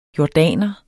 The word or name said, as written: jordaner